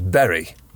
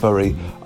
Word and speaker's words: Bury: At time of recording, it was believed that the correct pronunciation of Berry was indeed Berry. However, upon further research, it does appear that both Berry and Bury are seen as the correct way of pronouncing it, depending on which side of the town you are from.